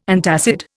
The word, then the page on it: antacid: Indian